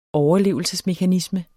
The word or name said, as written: overlevelsesmekanisme